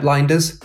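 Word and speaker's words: Lijnders: Pep Linders, and yes, that's how you pronounce it, is one of Klopp's right-hand men.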